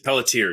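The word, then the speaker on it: Peletier: Carol, is it Pelletier, maybe? Is that how you pronounce it? – Pelletier, yeah.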